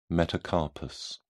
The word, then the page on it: metacarpus: British English